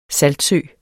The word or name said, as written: saltsø